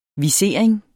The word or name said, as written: visering